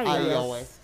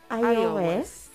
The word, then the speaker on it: iOS: iOS ¿Cómo se pronuncia? – iOS. iOS.